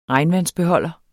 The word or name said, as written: regnvandsbeholder